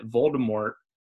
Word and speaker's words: Voldemort: Maybe you guys have heard the same thing, but I think I read somewhere one time that Voldemort was originally intended to have a silent T at the end. – Yes. – Like kind of almost a French-sounding pronunciation.